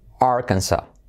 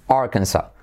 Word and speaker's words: Arkansas: Y como se dice en inglés, sorpresa, se dice Arkansas. Arkansas. Fijaos qué curioso, porque yo incluso siempre antes de saber esto decía Arkansas. Pensaba que incluso en inglés lo decían así. No, no se dice la última S, ¿vale? Arkansas.